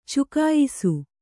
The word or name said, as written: ಚುಕಾಯಿಸು